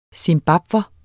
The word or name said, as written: zimbabwer